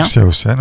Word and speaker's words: Hsiao-hsien: Bon, ça s'appelle donc Un été chez grand-père, un film de Yu Hsiao-hsien. Je ne sais pas si je prononce bien. – Hsiao-hsien, ouais, tout parfait.